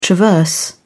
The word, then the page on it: traverse: British English